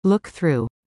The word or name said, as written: look through